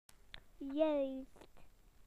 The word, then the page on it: correct: British English